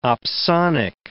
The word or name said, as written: opsonic